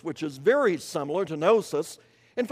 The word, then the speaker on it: gnosis: The word knowledge in 2 Peter 1 and verse 5 is translated from a Greek word gnosis, G-N-O-S-I-S, gnosis, a silent G as you might have seen, and it is a noun.